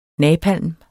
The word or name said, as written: napalm